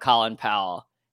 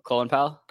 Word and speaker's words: Colin Powell: So essentially Trump came out with a statement about Colin Powell, and literally I just Colin Powell. – Really. Been saying it wrong my whole life.